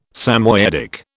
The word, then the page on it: Samoyedic: British English